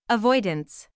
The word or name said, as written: avoidance